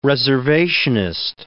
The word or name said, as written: reservationist